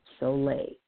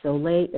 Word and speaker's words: sole: The first thing that I'm going to talk about is Himalayan salt solae. And solae is spelled S-O-L-E.